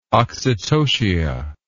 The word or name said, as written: oxytocia